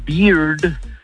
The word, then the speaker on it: bear: Porque con, o sea, la barba se pronuncia beard, el oso se pronuncia bear, ¿eh? Y la cerveza se pronuncia beer. – ¿Eh? – Pero con R al final.